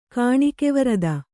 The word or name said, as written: ಕಾಣಿಕೆವರದ